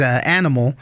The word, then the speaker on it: ANYmal: His name is Animal, but it's spelled A-N-Y-M-A-L.